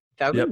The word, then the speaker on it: Feldman: Feldman? Yep, yeah, Feldman.